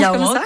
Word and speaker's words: Yaouank: Et Nora, à Rennes, on va pouvoir danser au festival Yaouank. – Ouais. – Ça se prononce Yaouank, comme ça.